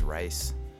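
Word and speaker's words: Wrice: I'm going to play That's On You featuring Umi by Joyce Wright. Damn, I just said her name wrong like 20 times. It's Joyce Rice.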